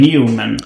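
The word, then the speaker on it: Neuman: Alltså det stavas E. Neumann men det ska uttalas E. Newman.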